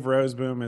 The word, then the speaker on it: Roseboom: I believe Roseboom is correct, but that extra little syllable may be in there.